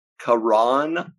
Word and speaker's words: Caron: This is Caron Butler.